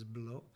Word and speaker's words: Blot: I'm sitting with Jean-Philippe Blot, or Blot. Yes. The proper French pronunciation is Blot.